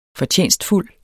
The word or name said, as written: fortjenstfuld